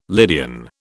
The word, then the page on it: Lydian: British English